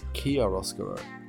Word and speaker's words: Chiaroscuro: Turns out I've been mispronouncing the album this whole time. It's actually pronounced Chiaroscuro.